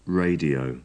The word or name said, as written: radio